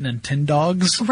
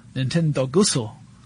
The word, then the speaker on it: Nintendogs: That was when Nintendo released Nintendogs. – Right, yeah. Was that originally for the DS? – It was for the DS, yeah. Nintendo Gusso.